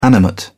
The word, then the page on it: animate: British English